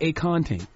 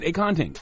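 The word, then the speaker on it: akonting: think about acorn drinking a ting. Mm-hmm. And that's how you pronounce it, acanting.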